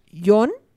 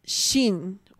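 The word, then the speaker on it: yon (四): El 4 tiene dos formas de pronunciarse. Hay una forma que es yon. – John. John, sí, como yon. Y hay otra que es shin o shi, ¿sí? Que se parece mucho a cómo se dice muerte en japonés.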